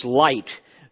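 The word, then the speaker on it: Lyte: And Henry, I think it's Light is the way it's pronounced, wrote, "Praise my soul, the King of heaven."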